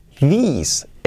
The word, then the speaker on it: these: Esto en singular se dice this, pero en plural se dice these, estos, escrito así, tese. These, these, these son.